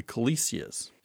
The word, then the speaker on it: Callisia: Then there's a whole other group called the Calisias, and that's spelled C-A-L-L. I-S-I-A. Do you think I said that one right? – Yes.